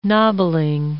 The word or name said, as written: nobbling